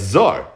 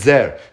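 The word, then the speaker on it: Zer (זר): Rabbi Yechon has to say, except the pasuk says, Zar, the Karinon Zer, meaning the pronunciation of the word is Zer. But if you look at the word, it looks like it says Zar.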